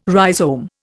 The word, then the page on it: rhizome: Indian